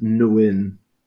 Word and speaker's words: Nguyen: Und vom Fleck kommt, ich hoffe, ich spreche es richtig raus, Henry Nguyen, hoffe ich, wird es ausgesprochen. – Ich glaube, es ist Nguyen. – Nguyen.